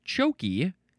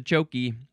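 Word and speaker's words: tjoekie: Well, it does say at the very end, it shows the word chokey, C-H-O-K-E-Y, so maybe that's how it's pronounced, but it is spelled T-J-O-E-K-I-E. I'm just gonna say chokey.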